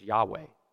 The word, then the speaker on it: Yahweh: When you see the Lord's name in all caps like that, you may know this already, but it in Hebrew, it's, well, the way we pronounce it in Hebrew, the best that people can come up with is Yahweh.